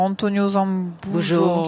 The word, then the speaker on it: Antonio Zambujo: Alors, on Portugal, était sur Portugal-RDC, on était sur Antonio Zambujo très bien prononcé.